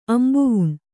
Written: ಅಂಬುವುಣ್

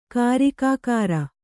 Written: ಕಾರಿಕಾಕಾರ

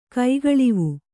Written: ಕೈಗಳಿವು

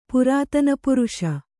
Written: ಪುರಾತನಪುರುಷ

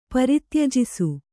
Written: ಪರಿತ್ಯಜಿಸು

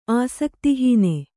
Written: ಆಸಕ್ತಿಹೀನ

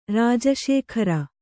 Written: ರಾಜಶೇಖರ

